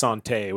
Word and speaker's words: santé: So it's most certainly pronounced santé, which is the French word for health.